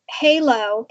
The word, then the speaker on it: Hèilo: So the name of the company is Hèilo, but it's spelt different. It's H-E-I-L-O. And we have an accent over the E to make it an A sound.